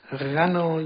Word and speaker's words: غنا: یک از ماده غنا، دوم غنا، یکی با فتح است و دیگری با کسر. غنا یعنی با فتح اگر تلفظ شود به معنای کفایت است، به معنای کفایت کردن و بسنده کردن.